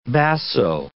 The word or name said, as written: basso